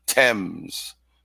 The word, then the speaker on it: Thames: That was like Thames television or whatever they called that. Thames.